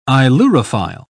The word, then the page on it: ailurophile: British English